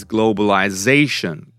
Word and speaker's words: globalization: But when it comes to saying the noun form, what is it? It's globalization. Zation, the fourth syllable.